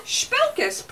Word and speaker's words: Spelkus: Did I say Spelkus right? – You said Spelkus perfectly.